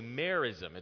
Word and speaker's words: merism: Okay, this is a figure of speech known as a merism. It's spelled M-E-R-I-S-M.